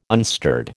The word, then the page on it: unstirred: American English